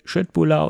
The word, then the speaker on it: Köttbullar: Ich habe extra recherchiert, ob es nicht Köttbullar ausgesprochen wird. Nein, es wird Köttbullar ausgesprochen. Das ist die richtige Aussprache.